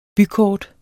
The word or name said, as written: bykort